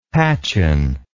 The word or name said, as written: patchen